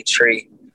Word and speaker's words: Tree: Jamaican is pronounced three, Tree.